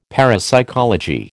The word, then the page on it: parapsychology: American English